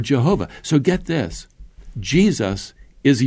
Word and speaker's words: Jehovah: We would pronounce it Jehovah.